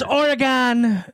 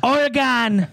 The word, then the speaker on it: Oregon: Dude, motherfuckers on the East Coast pronounce O's so weird. – No, it's Oregon. – Orange, Oregon. – Oregon.